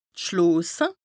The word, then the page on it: d' Schloose: Bas-Rhin; Strasbourg